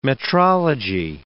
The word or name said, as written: metrology